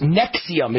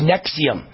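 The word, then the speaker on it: NXIVM: It's a sex cult, and it's pronounced Nexium. It's spelled N-X-I-V-M. N-X-I-V-M. It's pronounced Nexium.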